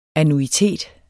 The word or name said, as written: annuitet